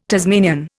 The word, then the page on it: Tasmanian: Indian